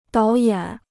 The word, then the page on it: 导演: dǎo yǎn